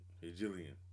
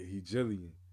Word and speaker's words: Hegelian: Hegelian. – Yeah, Hegelian.